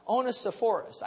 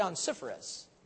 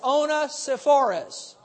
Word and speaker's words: Onesiphorus: Now, I didn't know how to say that until Tony Cook was here last time, and he told me how to pronounce that. Onesiphorus. I used to say Onesiphorus, but that sounded like a disease, so I stopped saying that. And let's say this, Onesiphorus.